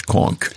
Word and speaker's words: conch: Is it conch or conch? – It's conch, yeah.